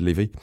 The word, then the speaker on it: Levi: It's spelt Levi, but it's said Levi.